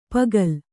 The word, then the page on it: ಪುಕ್ಕಲಿ: Kannada